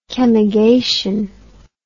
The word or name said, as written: chemigation